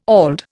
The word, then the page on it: auld: Indian